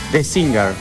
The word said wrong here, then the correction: The Sinner: Sí, no sé si lo dije bien, pero... – The Sinner. S-I-N-N-E-R.